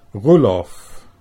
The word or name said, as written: Roelof